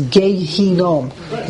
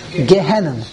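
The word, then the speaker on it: Gehinnom: Okay, you can say the whole Perek Shira, but when you come to this Gehinom Omer, okay, it's the way, real way to pronounce, by the way, is Gehinom, the Valley of Hinom. The world, the Olam, says Gehenna, Gehenna.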